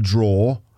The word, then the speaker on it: drawer: He was feeling reminiscent as he pulled open a desk drawer, and that word is pronounced drawer, D-R-A-W-E-R, drawer.